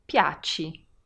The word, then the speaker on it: piaci: M-A-N-G-I. So how might you say you please? – Piaci. – Again with the you ending here. So I, pronounced as E. Piaci. P-I-A-C-I.